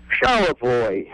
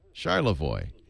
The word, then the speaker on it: Charlevoix: Charlevoix. – Charlevoix. All right. Charlevoix.